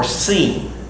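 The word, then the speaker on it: shin: And this is the sheen, and it can be pronounced sheen or seen.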